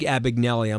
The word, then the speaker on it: Abagnale: Frank Bisignelli, great guy. – How fascinating was that speech? – Yeah, Frank Abagnale, I'm sorry. – That's right. – I mispronounced his name.